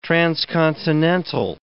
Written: transcontinental

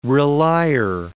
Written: relier

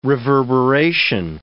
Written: reverberation

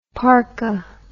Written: parkha

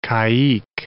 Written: caique

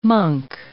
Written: monk

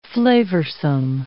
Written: flavorsome

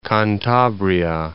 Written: Cantabria

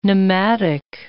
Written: pneumatic

